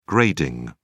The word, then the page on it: grading: British English